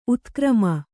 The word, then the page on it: ಉತ್ಕ್ರಮ: utkrama